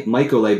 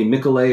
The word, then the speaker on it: Mikolay: Yeah, uh, Twitter at J M I K O L A Y J Mikolay. By the way, a lot of people call me Michaelay or Michaeloy. It's Mikolay with a long I.